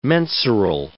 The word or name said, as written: mensural